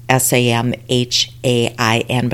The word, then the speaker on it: Samhain: And you see it as Samhain S A M H A I N, but it's pronounced Samhain.